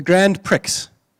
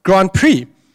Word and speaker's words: Grand Prix: One of those race cars from the Grand Prix." And so she laughed at me like that. I wasn't quite sure why she was laughing. And she said, no, my boy, it's actually pronounced Grand Prix.